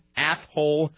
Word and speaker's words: Athol: A-T-H-O-L. – Athol. That's right. – Athol. – Where is Athol? – It's west of Worcester. – Uh-huh. When was Athol? – Athol is a town of 11,000.